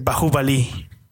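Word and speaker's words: Baahubali: De hecho la pronunciación correcta es Bahubali.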